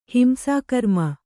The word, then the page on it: ಹಿಂಸಾಕರ್ಮ: Kannada